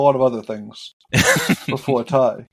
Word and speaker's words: Tadhg: Is that how you pronounce that? – T-A-D-H-G. Yeah, I had to Google that one. – It's Ty? – Ty, yeah. – Wow, okay. – It kind of looks like Taj.